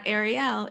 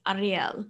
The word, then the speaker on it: Ariel: And so it's definitely, it's not Ariel, it's Ariel.